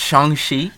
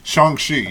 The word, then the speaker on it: Shang-Chi: I think it's pronounced Shang-Chi. – Shang-Chi, yes. – But Liu Kang? – Yeah. Yes, the forgotten Mortal Kombat character, yes. Shang-Chi. – Oh, man. – It sounds like Quan Chi, so.